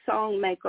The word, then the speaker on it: Songmaker: And recently I posted on YouTube, which you could go to Yoki Songmaker on YouTube. My name is spelled Y-O-K-I. Song, like you sing the song, Yoki Songmaker on YouTube.